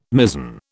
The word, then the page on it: mizen: British English